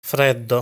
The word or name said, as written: Freddo